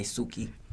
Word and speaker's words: Tzuki: Yeah, it's called Suki, actually. It's pronounced T-Z-U-K-I, Suki.